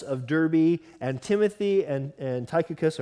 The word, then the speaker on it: Tychicus: I'm saying Tychicus. It's the totally wrong way to say his name. You're supposed to pronounce it this way, Tychicus.